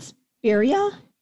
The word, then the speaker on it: spirea: Pink, how do you pronounce that? Spirea? – Yep.